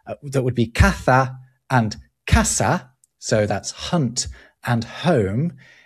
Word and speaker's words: casa: But in South America, where they use this ceseo, caza and casa are both pronounced the same as casa, casa.